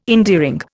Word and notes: endearing: Indian

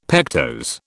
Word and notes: pectose: British English

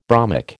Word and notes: bromic: American English

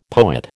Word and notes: poet: American English

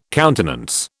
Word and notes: countenance: British English

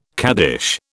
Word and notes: caddish: British English

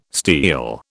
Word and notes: steal: British English

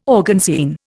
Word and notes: organzine: Indian

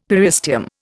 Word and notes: periosteum: Indian

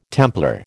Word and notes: Templar: American English

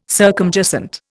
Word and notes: circumjacent: Indian

